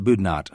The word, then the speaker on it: Boudinot: It's probably pronounced Boudinot, okay. Even though it looks like it would be Boudinot.